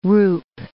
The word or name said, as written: roup